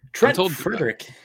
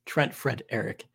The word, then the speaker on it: Trent Frederick: Trent Frederick. – Go ahead. – Continuing with the poll. I mean, you mispronounced his name, though. – Trent Frederick.